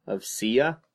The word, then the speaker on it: Sia: I'm a huge fan of Sia. And yeah. – I've been listening to her album a lot. I think the song. – Is that how you say her name? – Yeah.